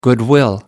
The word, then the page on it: goodwill: American English